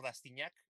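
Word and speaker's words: Rastignac: Y me encuentran en mis redes sociales, en Genaro M, en, LinkedIn, y me encuentran con Genaro Rastiñac en todas las demás redes, Twitter, Facebook e Instagram.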